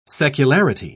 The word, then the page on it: secularity: British English